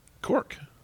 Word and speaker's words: Cork: You said Cook. – He corrected you. – Corrected your pronunciation. – Crown Cook and Seal. – How do you feel right now? You just got corrected. – No, it's Cork.